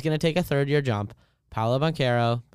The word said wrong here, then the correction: Banchero: Banchero, however you want to pronounce Banchero.